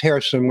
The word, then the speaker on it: Kherson: Some situations are massive rescues, like recently in Kherson, pronounced Harrison with the flooding.